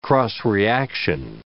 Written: cross-reaction

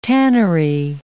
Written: tannery

